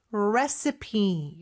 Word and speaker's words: recipe: 자, 레시피, recipe 이렇게 발음하고요. 근데 스펠링은 R-E-C-I-P-E예요. – Yes, I think the first time I saw this word, I thought it was recipe. – 맞아요. 그렇게 마치 읽어야 될 것처럼 보이지만 recipe라고 읽습니다.